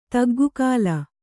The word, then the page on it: ತಗ್ಗುಕಾಲ: kannada